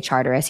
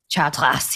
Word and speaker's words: Chartres: And whenever I say Charteris, he's like, it's Chartres.